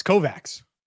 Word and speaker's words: Kovacs: According to Big Ten Network, it's Kovacs. So we were there's no CH. – Okay. – In the pronunciation there.